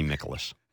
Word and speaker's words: Mikolas: Miles Mikolas. Mikolas. – You weren't close. – Well, Miles Mikolas. It should be Mikolas.